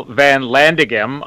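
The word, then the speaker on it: Van Landeghem: And I'm going to say Chantelle Van Landigem. – Yes. – That's how you don't butcher it.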